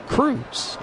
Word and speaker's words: Croes: Now, and Cruz spells his last name C-R-O-E-S. But it is pronounced Cruz. And I actually have it spelled in my book, C-R-U-Z.